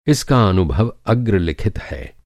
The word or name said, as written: अग्रलिखित